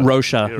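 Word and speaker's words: Rózsa: But anyway, listen to the original temp track, and it's from Ivanhoe, which was composed by, I'm going to say his name wrong, Miklos Rocha. – Rocha. Yeah, Miklos Rocha.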